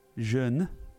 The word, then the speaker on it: Jeune: It's J-E-U-N-E. – Jeune.